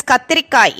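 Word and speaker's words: கத்திரிக்காய்: kathirikai in Tamil.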